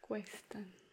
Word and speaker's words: cuestan: That O in this case becomes U and E, which is pronounced we. So cost becomes que. – Quest. – Cuestan.